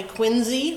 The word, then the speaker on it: Quincy: One is that, as Barbara Chatterton knows, the people locally do pronounce the town and the family Quincy, like a Z, but I'll be sticking to the more common pronunciation that we seem to have taken over.